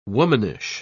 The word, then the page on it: womanish: British English